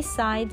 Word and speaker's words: sigh: Poppy sighed miserably. 那这个 Poppy 公主呢，就叹了一口气，非常哀伤的叹了一口气。 Sigh, S-I-G-H 不发音。